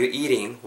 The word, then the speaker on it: eating: eating 발음하실 때 e a t i n g eat to eat something 말 그대로 뭔가 먹다라고 발음하실 때 it 이렇게 짧게 발음하시는 게 아니라 eat 좀 길게 발음을 하셔야 된다라는 거 우리 여러 분 배워서 알고 계시죠?